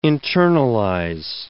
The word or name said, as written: internalize